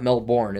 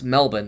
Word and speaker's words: Melbourne: It's not Melbourne, it's Melbourne. That's how they pronounce it over there.